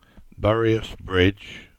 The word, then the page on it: Buryas Bridge: English